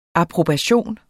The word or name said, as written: approbation